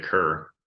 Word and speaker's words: Kehr: Yeah, I think it's spelled K-E-H-R, but you pronounce it Kerr.